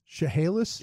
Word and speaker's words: Chehalis: How that city's pronounced? Chehalis? – Yeah. Chehalis.